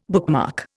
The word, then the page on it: bookmark: Indian